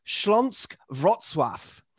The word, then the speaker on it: Śląsk Wrocław: and a line through the second L. This apparently means that you pronounce it Śląsk Wrocław.